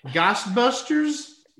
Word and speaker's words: Ghostbusters: And obviously, in 84, Dan Aykroyd had a pretty decent movie, Ghost Boosters? – Goosebusters. – I think I got my pronunciation wrong there. Ghostbusters. – Yeah, Ghostbusters.